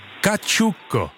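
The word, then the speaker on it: cacciucco: Kačuko. Takhle se to vyslovuje, já jsem si ověřil.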